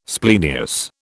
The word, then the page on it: splenius: British English